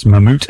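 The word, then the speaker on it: Mammút: This is going to be, I think it's pronounced Mammut, M-A-M-M-U-T, and with a hyphen thing above the U, so Mammut.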